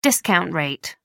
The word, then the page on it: discount rate: American English